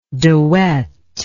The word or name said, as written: dewet